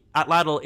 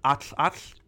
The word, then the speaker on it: Atlatl: And the Atlatl in that traditional pronunciation would be more of like an Atl-Atl, but for modern kind of sake, we call it an Atlatl.